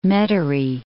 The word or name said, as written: Metairie